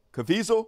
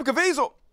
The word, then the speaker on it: Caviezel: So, Jim uh, Cavazil, somebody said it rhymes with weasel, Caviezel, is that how you pronounce it? Uh, Jim Caviezel Made a movie like eight years ago.